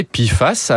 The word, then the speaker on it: PFAS: Et oui, on parle ici de composants chimiques très persistants et néfastes pour la santé, plus connus sous l'acronyme PFAS, prononcé PFAS.